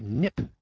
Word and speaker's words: Kneipp: Now, the following section on worms comes from an old book from 1896, which was entitled The Knipp Cure. That's spelled K-N-E-I-P-P, cure. Dr. Knipp was an herbalist, and he recommended many natural remedies.